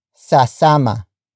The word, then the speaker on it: sasama: Sasama. Sasama. Sasama. Sasama is spelled as S-A-S-A-M-A.